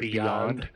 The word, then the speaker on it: BYOND: I guess you're supposed to pronounce it Beyond.